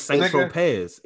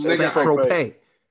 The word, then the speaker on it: Saint-Tropez: You said Saint-Tropez. It's Saint-Tropez.